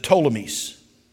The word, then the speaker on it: Ptolemies: Some of you in Egyptian history have heard about the Ptolemies. It starts with a P and then T. P silent.